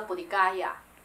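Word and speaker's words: 菩提伽耶: 当时的这个菩提树啊，是在今天的印度的一个地方，叫做茄野嘎亚，目前印度的地名叫做茄野，在以前的记载叫做菩提街，菩提那个字应该念怎么念？加或茄？菩提茄野，菩提加野，如果用他们的话叫菩提嘎亚，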